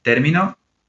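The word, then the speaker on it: terminó: Then termino is term, the stress in the first syllable. Termino is I finished. He termino, he or she finished. So different meaning according to the position of the stress.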